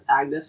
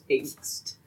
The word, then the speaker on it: Agnes: When I was little, I didn't know it was supposed to be pronounced Agnes, so I thought it was angst.